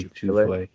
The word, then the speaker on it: Tufele: Even in other guys, like the guy at USC, Jay Tufele, I don't know if I said his name right. – Jay Tufele.